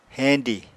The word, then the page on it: heñdi: apatani